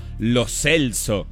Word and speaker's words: Lo Celso: Barsky arranca diciendo, no, bueno, porque lo Celso y nos vamos a tener que acostumbrar a decirle lo Celso. Y yo digo, para. ¿Qué? Al parecer le venimos diciendo lo Celso hace un montón de años. Y nadie le preguntó cómo se pronuncia. Y al parecer ayer hubo un video donde él arranca y dice, no, lo Celso.